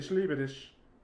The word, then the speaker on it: ich liebe dich: Like, and it's just like, even saying I love you, like, ich liebe dich. – Which, really? – Yeah, like, that's how you say it, ich liebe dich.